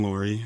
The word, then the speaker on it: Loory: My name is Ben Loory.